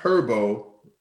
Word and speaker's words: Herbo: All right, that was um, Benjamin Broadway with Natural versus G Herbo lawyer fees.